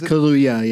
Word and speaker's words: Kaluuya: Is that how you say his name? – Yeah. – All right. Because that's also the name of a drink, right? Maybe I got it wrong. – Then it's you Kaluuya. Did get it wrong. It's Kaluuya, yeah.